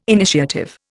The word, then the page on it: initiative: Indian